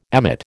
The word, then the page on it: emmet: American English